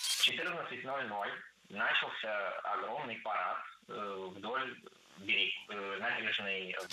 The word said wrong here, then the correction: начался: Начался неверно. Правильно, начался. Ударение в этом слове падает на последний слог, поближе к возвратной части «ся».